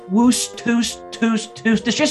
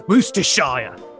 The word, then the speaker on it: Worcestershire: Since we invented it here in Worcestershire, I was thinking, Worcestershire sauce. – Oh, I think you mean Worcestershire.